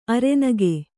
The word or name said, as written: ಅರೆನಗು